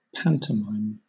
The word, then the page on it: pantomime: British English